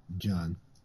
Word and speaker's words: Gian: Is it pronounced John or Gian? – John. – Okay, yeah, I thought they pronounced it John.